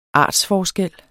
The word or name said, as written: ARTSFORSKELLENE